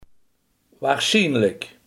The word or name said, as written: waarschijnlijk